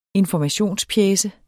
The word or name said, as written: informationspjece